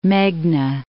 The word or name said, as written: Meghna